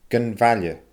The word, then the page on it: gun mhaille: Scottish Gaelic